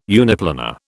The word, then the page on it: uniplanar: British English